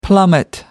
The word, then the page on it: plummet: American English